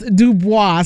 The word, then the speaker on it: Dubois: And let me tell y'all, it's funny because I didn't know how to pronounce his last name. Well, he got a whole song out here telling us how to pronounce it. It's called It's Pronounced Dubois.